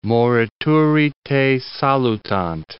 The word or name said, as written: morituri te salutant